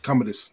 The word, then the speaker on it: Commodus: All right, I'm going to say his name wrong, but number 33, Commodus Gladiator. Whoever the main bad guy was in Gladiator. – Commodus, there you go.